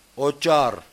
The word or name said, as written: Otchar